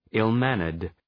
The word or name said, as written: ill-mannered